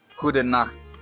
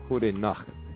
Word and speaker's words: goedenacht: And you also pronounce the T at the end. That's not silent. Hoede nacht. – Hoede nacht.